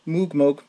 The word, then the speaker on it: Moog: I remember seeing at least parts of a documentary on Robert Moog, and I think it was pronounced Moog through the whole thing, if I remember correctly. – But, you know. – Yeah. Moog.